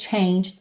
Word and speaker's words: changed: The heartbreak changed me. Changed, tá no passado. Lembra que a pronúncia não é changed, ok? A gente tem um ed aí, mas o e não é pronunciado. Então, the heartbreak changed me.